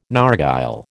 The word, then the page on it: narghile: American English